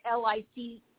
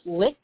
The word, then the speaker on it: Licht: They're mad at John Lick. I think it's pronounced Licht.